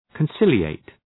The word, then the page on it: conciliate: {kən’sılı,eıt}